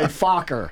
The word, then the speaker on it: Fokker: And he said that Richthofen flew a three-wing, and so I don't shock Jeff Sinis on the bridge, I'll spell it, a three-winged F-O-K-K-E-R. – Oh, yeah. I know. Poker. – It is a Fokker. – Fokker. – Right, there you go.